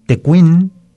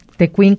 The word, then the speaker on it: tecuín: O sea, además de decirle tejuino, se le puede decir tecuín o también tecuino. – ¿Tecuín con acento? – Tecuín con acento en la I.